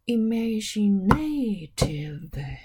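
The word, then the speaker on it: imaginative: で、イマジネティブですけれどもね、これもアメリカでは、イマジネーティブと、 A のところに、準脇役のアクセントが来てるらしいですから、これも後ろから 3 つ目、 A に、ちゃんとこう、タッタッタになってますよね。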